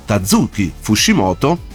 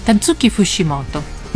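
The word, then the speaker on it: Tatsuki Fujimoto: Tazushi Fushimoto, Tazuki Fushimoto, ho detto bene? – Sì, Tazuki Fushimoto.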